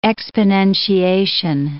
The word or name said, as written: exponentiation